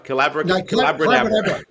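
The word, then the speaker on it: Collabracadabra: Calabra Cadabra. – It rhymes with Abracadabra.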